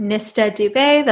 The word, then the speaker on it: Nistha Dube: So on Instagram, I am Nista Dubé. That's spelled N-I-S-T-H-A. D-U-B-E, my first and last name.